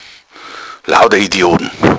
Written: idiot

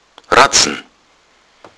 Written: ratzen

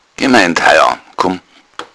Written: haia

